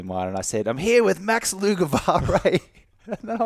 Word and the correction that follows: Lugavere: But yeah, it's Lugavere, like beer, an alcohol-free version of beer.